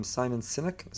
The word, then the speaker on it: Sinek: Simon Sinek, is that how you pronounce his name? – Yeah.